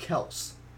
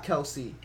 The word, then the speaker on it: Kelce: He's like, people have been pronouncing my name wrong my whole life. He says it's pronounced Kelce, not Kelce.